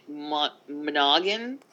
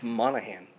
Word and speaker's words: Monaghan: Uh, so there was um, some images leaked of Dominic Monaghan. – Mo- do how you say his name? Dominic Monaghan.